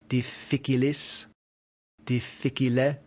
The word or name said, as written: difficilis